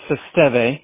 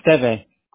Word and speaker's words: Esteve: Esteve. Like Esteve. Emilio Esteve is without the Z.